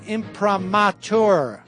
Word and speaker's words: imprimatur: This just in, it is pronounced imprimatur. Imprimatur. I've been pronouncing it correctly.